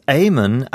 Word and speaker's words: Eamonn: Ask for Eamon Gardner. Shall I spell that for you? – Yes, please. – That's Eamon, as in E-A-M-O-N-N, and my surname is spelt G-A-R-D-E-N-E-R.